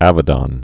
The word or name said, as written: Avedon